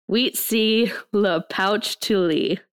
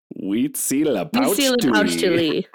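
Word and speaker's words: Huitzilopochtli: The way you say it is Huitzi Le Pouch Tuli. – Huitzi Le Pouch Tuli.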